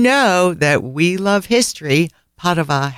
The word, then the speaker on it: Padova: Allora, let's chat happy things and about the fabulous little city of Padova, which Americans pronounce Padua or Padua, but in Italian, Padova.